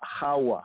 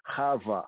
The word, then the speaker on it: Chava: So instead of the Isha, the first kind of naming nomenclature, then Adam called her Chawa, on some pronunciations Chava, which comes from Chawa or Chava, which means to speak.